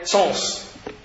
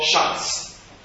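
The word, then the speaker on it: Sens: Actually, they say Sons. That's how they say it in French now. And in Hebrew, it's always called Shans.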